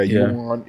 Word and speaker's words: yuan: Is that how you say it? The Chinese shit? Like Juan? Oh, like my boy Juan? – Like Juan? Yeah, like it's like yuan or something like that. – Yeah, yuan.